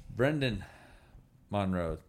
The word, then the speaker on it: Brenden Monroe: Brenden Monroe.